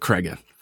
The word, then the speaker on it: Cregger: Hopefully I pronounced his name right there. – I think it's Cregger. – That too. All right, so Zach Cregger.